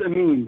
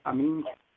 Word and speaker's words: Amine: It's Amine. The E is silent. It's just a legacy from being born in a French, or having the name that has a French spelling. So the E is silent, and it's Amine.